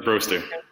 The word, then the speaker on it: Broaster: Yep, Broaster.